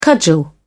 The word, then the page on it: cudgel: British English